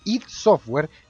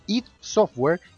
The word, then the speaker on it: id Software: El Wolfenstein 3D que ustedes conocen de id Software, a propósito, Chris Escobar, editor de Glitch, Glitch.cl, me dijo que se pronuncia id Software y no ID Software.